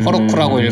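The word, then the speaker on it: Heroku: 제일 눈에 띄는 게 자기들 발음 기호에 대해서 명확하게 말했어요 음, 허로쿠라고 음, 읽어라 음.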